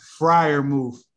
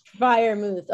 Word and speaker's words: Freiermuth: Pat Freiermuth. – Freiermuth.